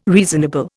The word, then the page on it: reasonable: Indian